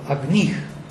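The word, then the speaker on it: agniḥ: Now, if you have agnih, and stress falls on the second syllable, agnih,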